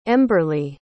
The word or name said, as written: emberlee